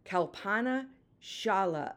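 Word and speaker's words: Kalpana Chawla: Kapana Shala, American astronaut. Whew, say that again. I didn't even pronounce it right. Let me say it again. Kalpana Shala, an American astronaut, said this: "The path from dreams to success does exist.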